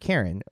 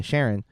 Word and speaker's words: Charon: However, The hotel manager is named Karen, C-H-A-R-O-N, or pronounced Sharon, who is the ferryman for Hades in the Greek mythology.